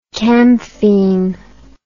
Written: camphine

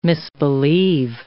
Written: misbelieve

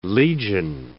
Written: legion

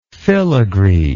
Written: filagree

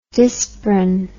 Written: disprin

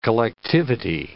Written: collectivity

